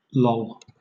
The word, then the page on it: loll: British English